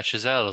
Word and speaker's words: Chazelle: because you say Chazelle, is that, I think that's how you say his name? – Yeah, Chazelle is what I would say, yeah.